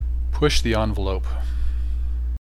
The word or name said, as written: push the envelope